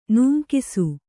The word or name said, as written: ನೂಂಕಿಸು